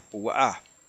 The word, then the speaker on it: Púa: Sí, en hebreo el acento es en la A, Púa.